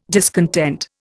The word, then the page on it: discontent: Indian